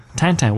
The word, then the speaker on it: Tintin: he kept pronouncing it Tintin. Tintin, which is evidently how you pronounce it.